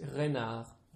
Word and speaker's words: renard: Yeah, it's renard. – Renard. – So it's that A-R-D at the end.